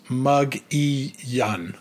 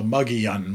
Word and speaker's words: Mogollon: But it's spelled out like Mogollon, M-O-G-O-L-L-O-N. The Mogollon Monster, but apparently it's pronounced Mug-E-Yun, so Mug-E-Yun Monster.